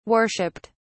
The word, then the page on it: worshiped: British English